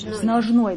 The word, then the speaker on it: ножной: Как правильно по-русски? Ножной браслет или ножный? Как? – Ножной, да, браслет. Ударение. Ножной браслет.